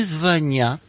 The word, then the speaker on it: звонят: Если они вам в ответ не звонят, ударение на окончании, то это значит, что у них все хорошо.